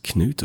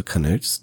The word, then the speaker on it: Knut: Later he's going to be entitled Canute the Great, Knut or Canute, depending on what sort of pronunciation you like, and he is the son of Sven Forkbeard, Knut the Great.